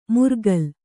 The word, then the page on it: ಮುರ್ಗಲ್: murgal